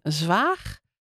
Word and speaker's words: Zwaag: Helma, jij bent doktersassistente in een huisartsenpraktijk in Zwaag.